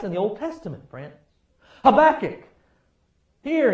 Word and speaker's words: Habakkuk: Now, sometimes you have to sound like you're coughing when you say that. Habakkuk. Habakkuk.